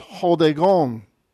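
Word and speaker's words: hodegon (ὁδηγόν): Rough breathing's at the beginning, so it's hodegon.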